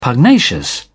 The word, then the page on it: pugnacious: British English